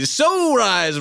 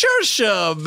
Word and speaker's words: Saoirse: And when they say, so rise, Ronan, I say, whoa now, I'll start moaning if you don't pronounce it right. It's Saoirse, baby. Say it all night.